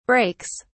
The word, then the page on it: breaks: British English